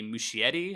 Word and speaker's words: Muschietti: Novel, directed by Andy Muschietti.